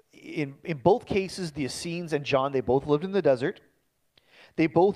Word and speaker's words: Essenes: The Essenes, I'm not sure if I'm saying that right. Pardon me? The Essenes. There you go.